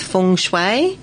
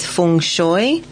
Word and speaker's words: feng shui: There are two correct pronunciations. The first is feng shui, and the second is feng shui. The second, feng shui, is how the Chinese generally pronounce the term, but feng shui is also accepted.